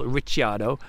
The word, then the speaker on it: Ricciardo: It's Daniel Ricciardo, because his surname is Italian, so forget the fact he was born in Australia.